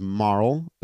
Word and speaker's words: Marle: introduces herself as Marl, who I think we used to call Marley, but I believe is properly pronounced Marl, and that's what I call her now.